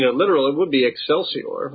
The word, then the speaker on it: Excelsior: No, I usually would even do a very classical Roman pronunciation of this would have been with a hard C as a K, Excelsior.